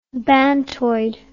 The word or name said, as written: bantoid